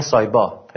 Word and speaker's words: سایپا: بگید آقا سایپای، اونا میگن سایبا، پیام تلفظ نمیکنن به تلفظ میکنن،